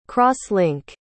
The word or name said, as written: cross-link